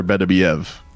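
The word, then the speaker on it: Beterbiev: Better Beef offers a title shot to Jake Paul. Is that something? – Not how you say his name. – Archer Betterbeef? – Archer Betterbeev.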